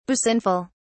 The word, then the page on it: basinful: British English